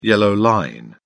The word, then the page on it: yellow line: English; British English